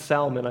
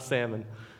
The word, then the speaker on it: Salmon: It is pronounced Salmon, I double-checked, it's not Salmon.